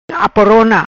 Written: Aporona

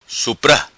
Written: Supra

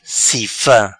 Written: Cifa

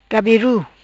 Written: Gabiru

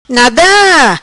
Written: Nada

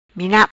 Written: Minap

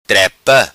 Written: Trepa

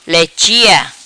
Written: Lechia

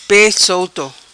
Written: Pe Solto

